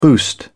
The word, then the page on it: boost: British English